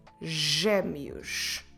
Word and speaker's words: gêmeos: when the letter G is followed by the vowels E, I. And every time that happens, no doubts there, you need to pronounce it as a soft J. Let's have a look at some examples. For instance, the Portuguese word for twins. Gêmeos. Gêmeos. Como se escreve gêmeos? G, E, com acento, M, E, O, S.